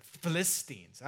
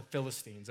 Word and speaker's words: Philistines: Philistines. I always thought he had the wrong emphasis on the wrong syllable. And so as far as here at Cross Church, they're called the Philistines, okay?